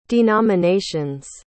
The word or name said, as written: denominations